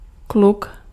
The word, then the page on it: kluk: /klʊk/